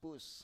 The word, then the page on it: pus: Biak